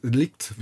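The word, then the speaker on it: de Ligt: Ja, der hat, der hat sich wirklich voll reingehauen äh, gegen Delikt, Delikt, Delikt wird da glaube ich ausgesprochen. Ja.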